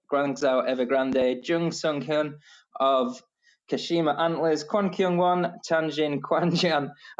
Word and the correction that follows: Tianjin: I'll leave you for the Chinese pronunciation, Matt. Tianjin Chunjin. Tianjin.